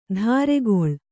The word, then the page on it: ಧಾರೆಗೊಳ್: dhāregoḷ